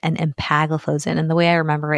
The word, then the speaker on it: empagliflozin: Canagliflozin, dapagliflozin, and empagliflozin. All right. So with that, nice job with the pronunciation.